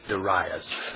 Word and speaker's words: Darius: I said it was either Darius or Darius, depending on which where you put the emphasis on the syllable. Well, I looked it up. The Hebrew accent is on the middle. It's Darius.